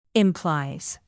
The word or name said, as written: implies